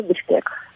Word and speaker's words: Bishkek: How do you pronounce that town? – The country or the city? – Oh, both. – Okay, it's country's Kyrgyzstan, and the city is the capital, Bishkek.